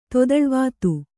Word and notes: ತೊದಳ್ವಾತು: kannada

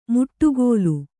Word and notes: ಮುಟ್ಟುಗೋಲು: muṭṭugōlu; kannada

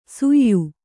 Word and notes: ಸುಯ್ಯು: suyyu

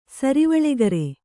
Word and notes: ಸರಿವಳೆಗರೆ: Kannada

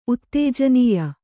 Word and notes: ಉತ್ತೇಜನೀಯ: Kannada

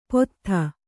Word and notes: ಪೊತ್ಥ: pottha

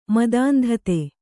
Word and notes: ಮದಾಂಧತೆ: madāndhate

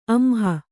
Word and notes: ಅಂಹ: amha

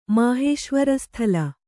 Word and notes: ಮಾಹೇಶ್ವರಸ್ಥಲ: māhēśvara sthala

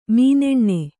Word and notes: ಮೀನೆಣ್ಣೆ: mīneṇṇe

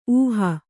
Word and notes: ಊಹು: ūhu

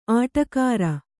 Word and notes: ಅಡಿಗಾರ: aḍigāa; kannada

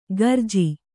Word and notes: ಖರ್ಜಿ: kharji

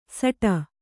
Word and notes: ಸಟ: saṭa

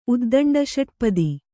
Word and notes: ಉದ್ದಂಡಷಟ್ಪದ: uddaṇḍaṣaṭpada

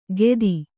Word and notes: ಗೆದಿ: gedi

